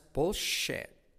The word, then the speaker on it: bullshit: That's bullshit. That's bullshit. SH, clave y como es shit. Y la T de shit casi no se dice. Es bullshit. Es bullshit.